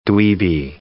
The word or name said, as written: dweeby